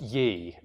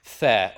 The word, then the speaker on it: the: Now, this is a complete, I won't say it's a complete lie, but at least it's something that's very ridiculous, because this word would never have been pronounced ye, right? So this is the definite article, and it would never have been pronounced ye. It would always have been pronounced the or th, right? The. This is just the word the, and it always was.